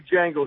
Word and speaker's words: Django: D is silent, pouring, like that movie Django. You don't pronounce the D.